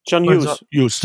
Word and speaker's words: John Hughes: John Hutches spricht man ihn so aus? – John Hughes. John Hughes, so, John genau. Hughes. John Hughes, Entschuldigung, genau.